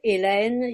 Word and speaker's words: Hélène: And what you brought up about Hélène, yes, the H is silent.